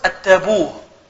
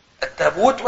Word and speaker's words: التابوت: ينطقون كلمة التابوت التابوه، التابوت والتابوه.